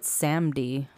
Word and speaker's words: Samedi: Samedi? – They pronounce it Samdi. – Samdi. Okay, that sounds good.